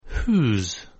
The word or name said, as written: who's